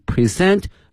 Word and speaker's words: present: Gather at Shaolin Temple to present different martial arts performances，这个词我们原来也讲过 present，注意一下它的发音哈 present，因为呢这个词的拼写 P-R-E-S-E-N-T 它的发音呢有两个哈，一个呢是 present，我们今天作为动词呢表示展示给某人什么什么东西哈 present，另外呢这个词还可以作为一个形容词，还有呢作为名词表示说礼物或者呢目前的意思，但是呢它的发音则发生了变化 present， present。